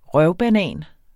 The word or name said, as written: røvbanan